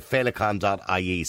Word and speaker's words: Feileacain: So if people want to help out, by the way, they can go to feileacain.ie. So now that's spelled F E I L E A C A I N.